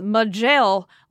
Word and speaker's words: Majel: By the way, Agent M, we pronounce Majel as Majel, like majestic.